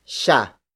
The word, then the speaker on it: siya: The next pronoun is siya. Siya. Siya. Siya is spelled as S-I-Y-A.